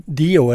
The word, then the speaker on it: Dio: Sånn som Dio, er det sånn du uttaler det? – Ja.